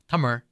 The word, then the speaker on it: Tamil: That's Tamil, spelled T-A-M-I-L in English. People will often pronounce it Tamil. In Tamil, it's actually pronounced Tamar.